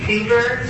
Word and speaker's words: Suver: Katie Suver, last name is spelled S-U-V-E-R, car number 975067.